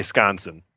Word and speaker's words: Wisconsin: If you're from Wisconsin, you rarely pronounce the W and the I. You usually just say Sconson.